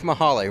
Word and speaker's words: Mahaly: Dave Mahaly. Rhymes with tamale.